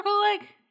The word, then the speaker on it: Coolidge: Was that Jennifer Coolidge? – Oh yeah, let me check. – I think that's how you say her name. – Yeah, it is.